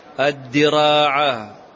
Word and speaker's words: الدراع: قال أبو بكر هو الأثرم قيل لأبي عبد الله هو الإمام أحمد بن حنبل اضبط معي الدراع بالكسر وهو ثوب صوف يكون لها فرج لا تنطق بها بغير هذا يكون لها فرج أي شقوق يعني أنواع من الثياب يكون فيها شقوق إما من جنوبها وإما من أمامها وإما من خلفها.